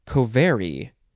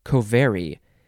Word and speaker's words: covary: I think you would pronounce it covary. You have to emphasize the ver, covary.